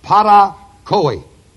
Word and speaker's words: parakoe: The word disobedience is the word parakoi. P-A-R-A-K-O-E, long E.